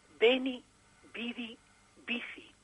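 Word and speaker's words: veni, vidi, vici: Y además tú has pronunciado correctamente el latinajo. Beni, vidi, bici.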